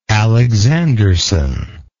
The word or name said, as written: Alexanderson